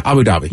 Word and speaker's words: Abu Dhabi: Am I saying that right? Yeah, Abu Dhabi. – You're right? – Saying it as right as I know it to be.